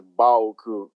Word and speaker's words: Baoku: Yes, my name is Hafiz and my last name is Baoku. Like Ba, like a sheep, O, letter O, and Ku.